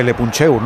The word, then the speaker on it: Le Puncheur: Le leemos ahí en, no sé cómo se dice, Le Puncheur, no sé cómo se dice eso, Sergio. – No, lo has dicho bien, lo has dicho bien.